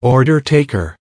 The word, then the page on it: order taker: American English